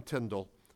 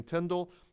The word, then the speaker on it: Tyndale: And one of those students is our subject tonight, William Tyndale. That's the most famous portrait of him. And it is generally believed by scholars nowadays that he pronounced his name Tyndale, because sometimes it's spelled phonetically as T-I-N-D-A-L-L.